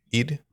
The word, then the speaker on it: Eid: Eid.